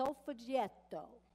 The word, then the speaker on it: Solfeggietto: It's called a solfeggietto. Repeat that after me. Solfeggietto.